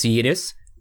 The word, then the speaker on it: Linus: I've seen a clip of an interview where he said, well, if he speaks Swedish, it's Yinus. If he speaks Finnish, it's Linus. But if he speaks American, sorry, but when he speaks English, it's Linus.